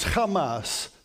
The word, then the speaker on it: Hamas: The word Hamas means zeal or in strength, but it's interesting that in Hebrew, the word, there's a very similar word. It is Hamas, so a slightly different pronunciation with a little bit of a guttural sound on the front end.